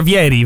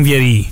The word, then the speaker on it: Vieri: Primo, si pronuncia Vieri, vero? Non Vieri. – Vieri. – Come Bobo.